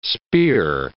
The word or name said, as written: spear